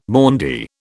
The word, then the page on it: Maundy: British English